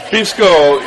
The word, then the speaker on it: pisco: Now tell us a little bit about your adventures with pisco last night. Did I pronounce it right? – Pisco, yeah.